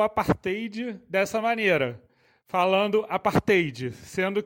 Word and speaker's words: apartheid: A pronúncia correta é apartheid,